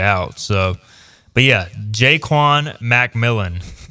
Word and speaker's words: McMillian: If Savon Revel and Jaquan McMillian, apparently it's Jaquan, by the way. Jaquan McMillan is the official pronunciation.